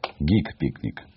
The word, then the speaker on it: гик-пикник: Я неправильно сказал. Гик-пикник, правильно говорить. Да, гик, хорошо, гик, да. А, от самого айтишного слова гик.